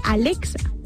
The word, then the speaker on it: Alexa: Arexa, o sea, como ellos no pueden pronunciar como la L, o sea, ellos son raros, porque dicen que no pueden pronunciar la R, pero a veces sí la dicen, porque dicen Arexa en vez de Alexa, pero como dicen aló, aló flito, pero aquí no, aquí es Alex, Arexa.